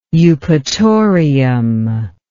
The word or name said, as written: eupatorium